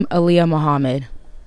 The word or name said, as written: Aaliyah Mohammed